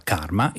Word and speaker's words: CCRMA: Il laboratorio del CCRMA, si pronuncia CARMA,